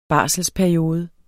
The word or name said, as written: barselsperiode